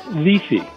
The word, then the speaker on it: Wi-Fi: The letter I in Polish gives us an E sound. So that's why Wi-Fi is pronounced Wi-Fi.